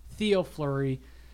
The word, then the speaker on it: Fleury: Theo Fleury. Rhymes with Chris Drury. – 818 points. Theo Fleury.